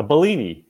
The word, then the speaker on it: Bellini: A Bellini, yeah.